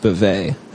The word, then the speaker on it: Spivet: You know, you have to say, do I want this film about T.S. Spivitt, and I actually believe it's pronounced Spivet.